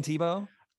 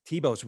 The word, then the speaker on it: Thiebaud: Thiebaud's really hard to spell. Don't ask me how to spell it. – T-H-I-E. T-H-I-E. B-A-U-D. T-H-I-E. Thiebaud.